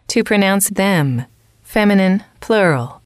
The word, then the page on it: to pronounce it: female